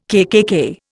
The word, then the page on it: KKK: Indian